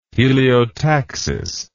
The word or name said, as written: heliotaxis